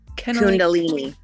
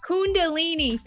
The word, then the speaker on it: Kundalini: Kundalini. – Kundalini, thank you.